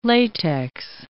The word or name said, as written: latex